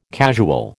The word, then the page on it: casual: American English